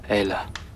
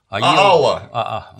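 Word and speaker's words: Ælla: Элла. – Аела. – Ну, он Аела у них. Да. Ну, Элла, хер поймешь. – Ну, Аела это по латыни, потому что АЕ дифтонг читается как Э оборотное.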